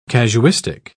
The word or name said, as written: casuistic